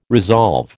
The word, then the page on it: resolve: American English